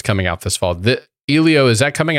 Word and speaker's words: Elio: Elio, and it's not Disney Animation.